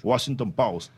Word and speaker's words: Washington Post: Luego una reunión con la directiva del Washington Post. ¿Lo dije bien? Post. – Correcto. – Washington Post.